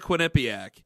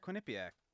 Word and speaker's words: Quinnipiac: Wait, you just said it wrong. You just said Quinnipiac. – No. – Quinnipiac. – Yes, thank you.